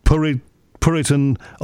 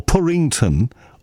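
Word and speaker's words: Purrinton: This place, Puritan or Purrington or Purrington, it can be pronounced either, I understand.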